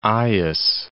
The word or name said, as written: Aias